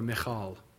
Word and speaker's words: Michal: Now, verse 20, some people pronounce it Michael, but we got a couple few Michaels in here, so I won't do that. We'll call her Michal. Sound more Hebrew doing that anyway, don't I?